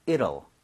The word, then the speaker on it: it'll: 이 T 사운드가 약간 flat이 나요. 그러니까 near 비슷하게 발음이 돼요. – 네. – 그래서 저는 it will 이렇게 하는 것보다는 it'll.